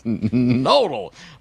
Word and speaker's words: Knodel: Insurrectionist Knodel. Or perhaps it's a silent K. I don't care to learn your name properly, as I find you to be an insurrectionist. I'll learn when your indictment drops. – It's Knodel.